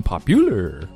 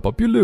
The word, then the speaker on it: popular: 所以这种呢我们在学长单词的时候一定要注意重音的位置啊，比如说 interesting, international, 还有 popular 等等。我之前见到一个同学把 popular 读成 popular，然后一股浓厚的撸羊肉串的味儿就出来了， popular, popular，重音一定要注意，一定要读准，多查字典，错误一旦形成很难改啊。